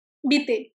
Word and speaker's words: Vitae: ¿Es Vitae o Vita? – Vite, se pronuncia. Vite, perdón, sí.